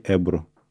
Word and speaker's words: эбру: И с 2014 года занялась творческой карьерой и сейчас э, художник в стиле эбру. Правильно сказал слово эбру? Эбру, да.